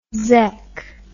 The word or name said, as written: zek